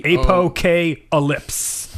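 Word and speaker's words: Apokolips: But it's not, but it's Apocalypse spelled like the DC planet. – Apocalypse A-P. – Okay, A-L-I. – A-P. – Apoke-alypse. L-I-P-S.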